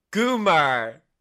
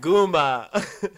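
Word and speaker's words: goomah: Goomer. It's a goomer. But it's pronounced Guma.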